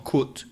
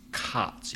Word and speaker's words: cut: Just one point with the pronunciation of cut, because it's often mispronounced by Spanish speakers who say cut. It's not cut, it's cut. You have to abrir la boca, open the mouth, the same sound as up, cut.